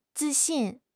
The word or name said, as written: zi4 xin4